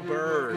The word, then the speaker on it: Bure: Pavel Blue. No, it's B-U-R-E. – Oh, Bure. Oh, Bure. – And it's pronounced Pavel Bure. Okay. Because he's Russian.